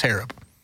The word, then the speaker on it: Harab: And it is Harab. – Harab. What's up? Harab. No, it's Harab. Like a scarab? – Like, Harab. Yeah, like scarab.